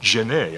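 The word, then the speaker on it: Genée: And this is where this wonderful, interesting character called Richard Genet, I think it's pronounced, not as in Jean Genet, but G-E-N-E-E,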